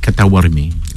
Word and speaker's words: Ktawarmi: Le Ktawarmi. – Ktawarmi, coupe, c'est ça. – Je prends des cours de prononciation cette année avec Nora. – Il faut bien prononcer le K.